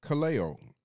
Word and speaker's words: kaleo: So this word call or called is pronounced kaleo in the Greek, and it's the word that's specifically used.